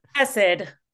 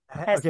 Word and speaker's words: Hesed: Hesed. – Okay. – That's good. – Wait, Did you say like acid? – Hesed. – Okay, gotcha. – So the stress is on the first syllable.